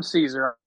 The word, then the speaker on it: Cesare: Is it Caesar? Is that how you say it? Caesar? – Yeah, Adam Caesar. Yeah. I keep wanting to say Cesare or Caesar or something like that.